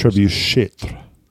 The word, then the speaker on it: trebuchet: Trebuchet. – It's trebuchet. It just ends in E-T.